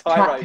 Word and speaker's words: Tiro: Did I say that right? Tiro. – Tiro. – Tiro. I knew I messed that up after all these years.